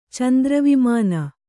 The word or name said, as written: ಚಂದ್ರವಿಮಾನ